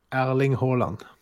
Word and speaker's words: Erling Haaland: Erling Haaland. – Remember that name and remember that pronunciation.